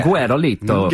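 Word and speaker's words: Guerolito: Guerrilito? – Yeah. – Guerrilito.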